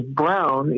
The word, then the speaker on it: Braun: I noticed that my junior senator is pronouncing his name as Brown, even though it's spelled Braun.